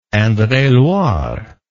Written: indre et loire

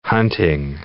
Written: hunting

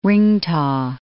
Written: ringtaw

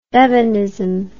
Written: Bevanism

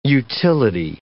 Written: utility

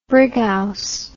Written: brighouse

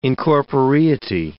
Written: incorporeity

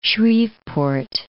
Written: Shreveport

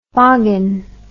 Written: Foggin

